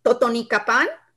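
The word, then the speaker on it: Totonicapán: And Griselda, correct me if I'm wrong, if I pronounce this incorrectly, Totonicapan? – Yes, that's correct.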